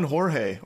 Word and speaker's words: Jorge: Blame it on Jorge, or blame it on George if you're an idiot that keeps mispronouncing his name.